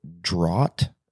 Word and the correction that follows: draught: D-R-A-U-G-H-T. And he said that it was pronounced draft, like laugh, L-A-U-G-H. – Uh-huh. – Draft, D-R-A-U-G-H-T.